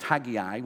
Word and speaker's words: Haggai: It's always weird that it's pronounced Haggai when it's spelled Haggai, but I leave that up to you to work out.